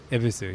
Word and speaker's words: Ebisu: We had a an Airbnb just in between Shinjuku and Ebisu? – Ebisu, yeah. Ebisu. Yeah. Ebisu. – Yeah. Which is what the beer is named after, but it's like a silent Y.